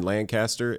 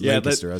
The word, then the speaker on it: Lancaster: I'm so happy to be here in Lancaster. And everybody was like, I was like, what did I do? – Record scratch. – Yeah. And they were like, it's pronounced Lancaster.